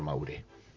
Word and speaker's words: Maure: Héctor, por el bandoneonista de la orquesta, Héctor Varela, y buscaron un apellido con el maestro y les dijo, te vas a llamar como mi esposa, Maure, pero con acento en la E. Allí comenzó una leyenda del tango, Héctor Maure.